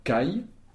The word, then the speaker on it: kaj: On trouve J aussi à la fin de certains mots, par exemple kai, pour dire E, s'écrit K-A-J.